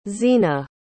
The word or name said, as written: zina